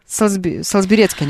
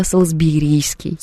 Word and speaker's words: Салсберийский: Уже целые прям научные трактаты пишут, как правильно говорить, Салсберецкий или Салсберийский. Все-таки правильно Салсберийский.